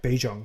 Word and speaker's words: Bijan: It's like Dijon mustard. Like, I think we've been saying it right. It's like Bijan because we're Bijan.